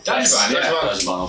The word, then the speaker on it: Dajiban: Is it pronounced Dajibon? – Dajibon, okay.